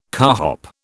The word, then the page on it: carhop: British English